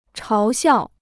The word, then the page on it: 嘲笑: cháo xiào